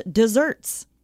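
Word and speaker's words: just deserts: Also, while we're on the topic, just desserts is things you deserve. And that's why it's spelled with one S, even though it's pronounced desserts.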